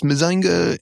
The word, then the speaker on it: Mazinger: I am so glad that nobody wrote in, like, in the comic book guy persona, like, actually, it's pronounced Mazinga, and you were incorrect.